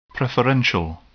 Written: preferential

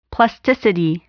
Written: plasticity

